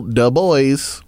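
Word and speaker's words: Dubois: No, but you're pronouncing that wrong, by the way. It's Daniel Du Bois.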